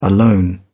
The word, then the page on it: alone: British English